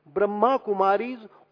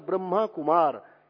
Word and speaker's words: ब्रह्मा कुमारी: इसलिए इनको कहा जाता है राजयोगिनी तपस्वी मूर्ति बहने ब्रह्मा कुमारीज और ब्रह्मा कुमार। एक बात और कह दूं सभी संतों से, यहां कुछ लोगों ने उच्चारण किया है ब्रह्म कुमार, ब्रह्म कुमारी, ब्रह्म कुमारी आश्रम नहीं है, ये ब्रह्मा कुमारी है।